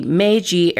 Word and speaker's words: Meiji: So we're going to start with the Meiji era of Japan. That's spelled M-E-I-J-I.